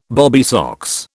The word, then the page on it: bobby socks: British English